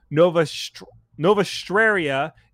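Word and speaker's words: Novastraia: Now, Nova Straria is threatened by... – Terrible name. – Did I butcher that? I probably butchered that. – No, that sounds right. It's just bad.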